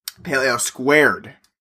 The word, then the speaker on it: Pale Ale Squared: Just this Saranac Pale Ale 2. – Pale Ale Squared. – Wow. – You got to say it correctly.